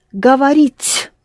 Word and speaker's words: говорить: To speak is говорить. So, to speak is говорить. Говорить. Говорить. Government. Говорить. In the government, they speak a lot, don't they? Any government, Russian or British. Говорить. Ри is soft.